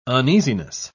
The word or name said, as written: uneasiness